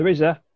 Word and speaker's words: RZA: Also appearing will be the RZA. Is that how you say it, isn't it? – Yep.